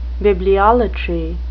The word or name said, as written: bibliolatry